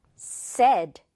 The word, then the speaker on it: said: 하지만 여기에 지금 이렇게 s가 오면 이 발음은 어떻게 되냐면 said, 이렇게 발음이 된다는 사실. said가 아니라는 거 꼭 주의를 해야 합니다.